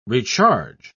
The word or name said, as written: recharge